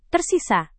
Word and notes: tersisa: Indonesian

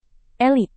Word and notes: elit: indonesian

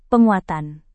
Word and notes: penguatan: indonesian

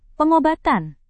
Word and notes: pengobatan: indonesian

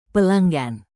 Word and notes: pelanggan: Indonesian

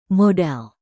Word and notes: modal: Indonesian

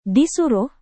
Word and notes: disuruh: Indonesian

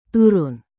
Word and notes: turun: indonesian

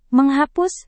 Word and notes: menghapus: Indonesian

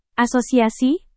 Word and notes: asosiasi: indonesian

